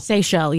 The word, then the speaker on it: Seychelles: Seychelles, yeah. – The Seychelles, islands. – S-E-Y-C-H-E-L-L-E.